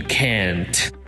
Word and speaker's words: can't: We hardly ever pronounce the final T in can't, so you may say it a little longer in the pronunciation.